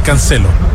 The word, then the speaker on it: Cancelo: Busqué la pronunciación, incluso para estar seguro, busqué la pronunciación. Y sí, es Cancelo.